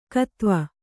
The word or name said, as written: ಕದವೆ